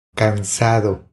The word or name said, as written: Cansado